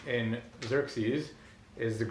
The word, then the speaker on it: Xerxes: Greek doesn't have a shin, so they have a real problem with Chayarsha. But they do have a Cha, they have Xerxes, but then we pronounce it as Xerxes.